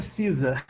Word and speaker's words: SZA: A pronúncia é Cisa, mas se escreve S-Z-A, né? S de sapo, Z de zabum, Bá de amor.